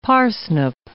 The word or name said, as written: parsnip